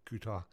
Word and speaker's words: Qatar: Since our news is on a 30-minute delay from the eastern states, Qatar, Switzerland, full-time, one each for Qatar, is that how it's pronounced? Same as the airline.